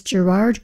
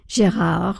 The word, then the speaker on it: Gérard: This viola concerto is in D and the soloist is Gerard Cosset, Gérard, who would be the French pronunciation.